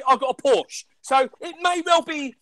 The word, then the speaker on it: Porsche: The correct way to say Porsche is Porsche.